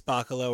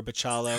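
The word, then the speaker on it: Bachalo: I'm a humongous fan of Chris Bacalo or Bacalo. I've heard it pronounced both ways by people in the industry, so I don't judge anybody's pronunciation of it until Chris tells me otherwise.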